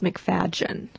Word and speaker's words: McFadyen: Usually it's pronounced McFadyen. – McFadyen. – For some reason, the Y is pronounced G, but okay.